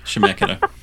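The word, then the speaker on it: Chemeketa: No, it starts with a C-H. – Shemekata. – Is that what it is? – Yeah.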